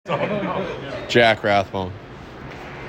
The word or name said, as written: Jack Rathbone